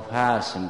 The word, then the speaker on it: فسم: یکی از چیزهایی که به اون تعبائل به طلب ها میاموختن همین فرق بین قسم و فسم بود، که قاف و فا در تلفظ یکسان نیستن، معنای قسم و فسم هم یکسان نیست. اون شکستنی که سر صدا داره و اجزایش کاملا جدا میشه با قاف ادا میشه میگن قسم.